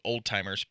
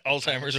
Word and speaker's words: Alzheimer's: I was as a kid, I always called it old-timers. But Alzheimer's or whatever it is.